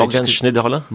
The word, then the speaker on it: Schneiderlin: Morgan Schneiderlin? – Morgan. Is that how you pronounce it properly? – Yes, it's from Alsace, yes.